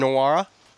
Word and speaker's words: Nwora: They're led by Jordan Nwora.